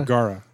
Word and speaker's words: Vergara: Is it Vergara? – Vergara. – Vergara. There we go. There you go.